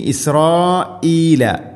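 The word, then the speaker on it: ഇസ്രായീല (إسرائيل): ഇനി ഇസ്രായീല, ഹംസ കൃത്യമായിട്ട് ഉച്ചരിക്കണം. പലപ്പോഴും ഓതിപ്പോകുന്നതൊക്കെ ഇസ്രായീല എന്നാണ്, ഇസ്രായീല, അങ്ങനെയല്ല, ഇസ്രായീല.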